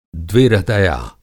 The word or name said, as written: द्विहृदया